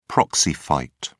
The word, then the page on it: proxy fight: British English